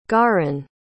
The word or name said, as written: garren